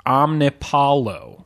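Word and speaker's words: Omnipollo: Also, someone mentioned us on Twitter, I think today, that a friend of theirs who is familiar with that brewery claims that it is pronounced Omnipolo.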